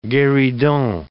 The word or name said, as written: gueridon